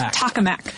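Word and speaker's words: tokamak: How did we decide that this is pronounced? – Tacomac. Yeah, We've got Tacomacs here in Atlanta, yeah, so it keeps throwing me off.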